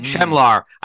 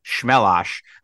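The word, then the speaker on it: Chmelař: Apparently, it's pronounced Shmelosh. It looks like Chemlar. But I thought it was Melar, but the R in the Czech language and Slovakian, it's this S sound, and I'm baffled by it.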